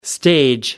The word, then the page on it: stage: American English